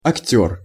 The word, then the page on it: akter: Russian